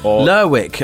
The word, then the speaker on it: Lerwick: Is it Lerwick or Lerwick? – Lerwick, if you're talking Shetland Islands.